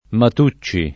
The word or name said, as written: Matucci